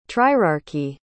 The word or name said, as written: trierarchy